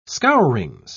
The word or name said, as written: scourings